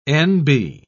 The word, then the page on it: n.b.: British English